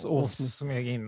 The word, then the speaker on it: Åsmegin: Åsmegin, ja det stavas Åsmegin, men det uttalas Åsmegin.